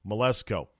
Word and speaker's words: Mleczko: Olympian A.J. Melesko, I believe is how you pronounce her last name. M-L-E-C-Z-K-O. Melesko.